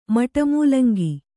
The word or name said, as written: ಮಟಮೂಲಂಗಿ